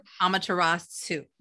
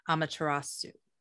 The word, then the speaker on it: Amaterasu: Amaterasu. So the last part is like T-S-O-O is how it's pronounced. Amaterasu.